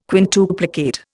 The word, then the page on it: quintuplicate: Indian